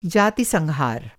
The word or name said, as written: जातिसंहार